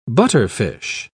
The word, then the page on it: butterfish: British English